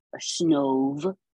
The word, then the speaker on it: Snov: I almost wanna say snob, but it's snob. Snove, S-N-O-V.